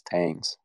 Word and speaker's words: Tangs: It's Tangs. The Tang hangs.